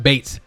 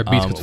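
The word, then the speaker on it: Beetz: Her name is pronounced Bates. Zazie Bates. – Okay, but I'm still calling her Beats.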